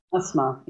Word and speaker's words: Asma: And his wife, how does she say her name? Asma? – Asma, yes.